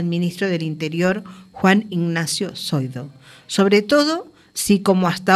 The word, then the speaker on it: Zoido: Dice, una juez advierte a Zoido, y bien pronunciada la Z, de consecuencias penales si no ordena mejoras en los CIE de Algeciras y Tarifa.